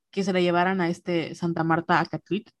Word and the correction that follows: Acatitla: que así lo pronuncié bien, ¿verdad? Santa Marta Acatitla.